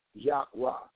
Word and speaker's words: Yahweh: Lord God was added, but what was removed is the Semitic four letters, YHWH, proper pronunciation, Yahweh.